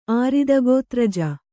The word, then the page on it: ಆರಿದಗೋತ್ರಜ: Kannada